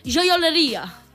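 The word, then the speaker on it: gioielleria: Giorgeria. Seria mamma mia. – Doncs ho sento, nenes, però qualsevol italià ens agafaria i amb una pizza ens fotria la cara. Perquè la pronunciació d'això és joioleria.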